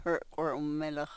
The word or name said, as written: hrkwrh u-melox